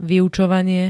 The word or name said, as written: vyučovanie